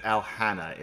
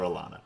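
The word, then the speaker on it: Alhana: And to Sidoran's question, because isn't it Alhanna in pronunciation? I've heard that. I don't like it. So I call her Alana. – Alhanna? – Yeah. Because it's spelled Alhanna.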